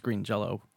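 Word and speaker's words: Green Jellö: It's still pronounced green Jello. – Right, right. – Yeah. – Because it's the Y with the umlaut over it.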